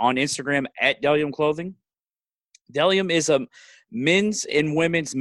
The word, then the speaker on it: Bdelliom: Delium is pronounced B D E, or I'm sorry, it's spelled B D E L L I O M right there. Boom, boom, boom, boom. It looks like it's spe- it looks weird because it's spelled with a B, but it's Delium Clothing.